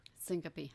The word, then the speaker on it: syncope: You were transported to the hospital and diagnosed with, I hope I'm pronouncing this right, vasovagal syncope? – Syncope. – Uh-huh. Syncope, I'm sorry.